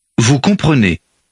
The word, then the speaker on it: comprenez: Vous comprenez. – Notice the E in the middle of the word. – Comprenez. – There's no accent mark on it or any other reason to pronounce it.